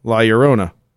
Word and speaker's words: La Llorona: The La Llorona is pronounced, yeah, La Llorona. Two L's together in Spanish make a Y sound, like El Pollo Loco.